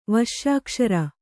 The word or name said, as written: ವಶ್ಯಾಕ್ಷರ